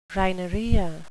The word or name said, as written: rhinorrhea